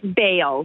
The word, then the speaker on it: Bale: Is it Bell? – It's Bale. – Bale. – Yeah. – Okay. – B-A-L.